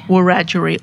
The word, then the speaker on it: Wurundjeri: Tara is a Wurundjeri author, is that right? – Wurundjeri author.